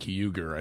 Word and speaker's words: Uygur: Yeah, Cenk Uygur, I think is how you say his name.